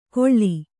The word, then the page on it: ಕೊಳ್ಳಿ: kannada